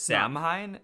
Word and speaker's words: Samhain: Yeah, I mentioned how to spell it because if you were reading it, you'd be like Samhain, but it's Samhain. Yeah, how do you spell it? – S A M H A I N. – What? – Yeah, it's Celtic. Celtic letters are pronounced differently.